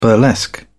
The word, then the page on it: burlesque: British English